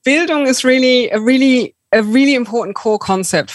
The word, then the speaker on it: Bildung: Yeah, Bildung, super.